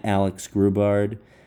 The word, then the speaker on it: Alex Grubard: You can follow me at Alex Grubard, A-L-E-X-G-R-U-B-A-R-D.